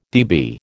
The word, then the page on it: dB: American English